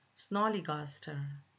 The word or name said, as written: snollygoster